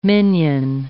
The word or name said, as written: minion